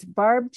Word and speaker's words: Barbe: It's Barbe Chambliss author, but Barb has a silent E on it. – Okay. – A-R-B-E.